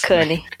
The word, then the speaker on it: Cannes: Cannes. Francês você não pronuncia S no final.